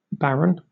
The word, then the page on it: baron: British English